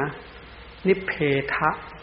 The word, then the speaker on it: นิเพทะ: ออกเสียงให้มันถูกไม่ใช่นิเพทนะนิเพทะ